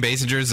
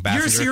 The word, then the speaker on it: Basinger: Well, and that's supposed to be like, you know, like, I mean, Kim Basinger's there. I'm saying it wrong. Bassinger. – You're fine. – Okay. – Basinger.